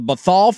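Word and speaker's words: Bhathal: The family that owns that team, the Bethal family, am I saying that correctly? – I believe that's correct.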